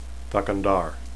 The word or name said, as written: thakandar